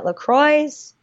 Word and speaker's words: La Croix: I love coconut LaCroix. It's actually pronounced La Croix. Did you know that? – No, it's not. – French. Look it up.